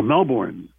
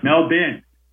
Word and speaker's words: Melbourne: We lost Melbourne. – Oh, Melbourne. Melbourne. – That's not the same thing. – Melbourne, yeah. – Oh, wait a minute. Melbourne. – There's no R when you pronounce it in Australia.